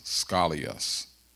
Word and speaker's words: skolios: So I took the time to look it up in the Bible dictionary, and it's a Greek word pronounced or spelled S-K-O-L-I-O-S, scolios, and it means warped.